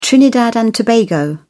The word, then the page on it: trinidad and tobago: British English